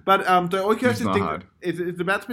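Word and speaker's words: oikeiosis: So this is actually, I guess, somewhat related to another terminology that the Stoics like to use called oikiosis.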